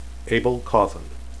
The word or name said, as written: abell